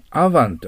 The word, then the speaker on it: oven: これでオーブンと読まないようにアヴァンと読みます。